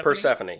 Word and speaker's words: Persephone: Am I saying that right? Persephone. – Persephone. Yes, that is correct.